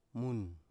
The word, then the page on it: mun: Biak